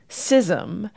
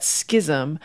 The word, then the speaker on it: schism: This word was borrowed in from Old French in the Middle English period, and the spellings indicate that it was pronounced schism, and new pronunciations, in particular that schism, seem to become more widespread in the 20th century.